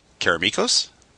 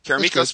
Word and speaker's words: Karameikos: So my number five is applies specifically to Karameikos. – Sure. – Is that how you say it? – Yeah. – That's how I'm saying it. – That's good. – Karameikos specifically and Mystara as a whole,